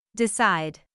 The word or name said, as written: decide